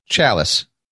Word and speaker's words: Challies: How do you say his name? Chalies? Chalies. I read his blog often and just realized I don't know how to say his name. – Yeah. Well, we'll go with Chalice.